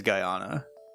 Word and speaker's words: Guyana: If I'm reading the pronunciation of this correctly, it was bothering me, so I looked it up. It is Guyana.